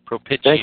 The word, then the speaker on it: propitiation: Propitiation. – Thank you. Propitiation.